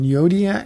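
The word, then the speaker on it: Euodia: These are two women, Yodia and Syntyche, is how you pronounce the names.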